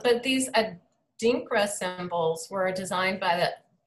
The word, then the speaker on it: Adinkra: So they're called Adinkra symbols. I don't know how to pronounce that word. It's A-D-I-N-K-R-A. Adinkra? – Adinkra? – Adinkra? Adinkra? Okay, so they're symbols that originated in West Africa.